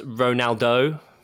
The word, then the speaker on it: Ronaldo: Cristiano Ronaldo? Is that it? – I believe it's pronounced Ronaldo.